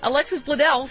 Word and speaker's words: Alexis Bledel: So, Alexis Bledel, I said it right. – You did it correctly.